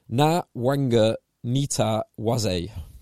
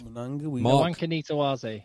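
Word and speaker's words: Nawaqanitawase: Wait, I did look this up because his Wikipedia page has a phonetic pronunciation of it. Give me 1 second. Pronounced, here we go. Pronounced Nawanga Nita Waze. Nawanga Nita Waze. – Nawanga Nita Waze.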